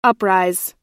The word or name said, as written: uprise